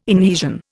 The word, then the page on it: inhesion: Indian